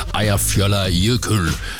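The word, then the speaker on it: Eyjafjallajökull: Eyjar fjallar jökull. Mener jeg jo, at den korrekte udtale på et islandske vulkan, der gik i udbrud det her år, skabte en askesky, der nedlagde flytrafik i massevis.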